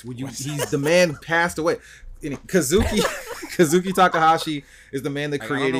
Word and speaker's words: Kazuki Takahashi: The name is pronounced precisely as Kazuki Takahashi.